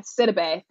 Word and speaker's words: Sidibe: Like I remember being in a workshop once, and it was with, I'm going to mispronounce her name, but Gabby Sidibe. Sidibe, thank you.